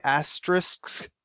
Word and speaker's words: asterisk: Like if you pronounce the word risk at the end, you can really get asterisk.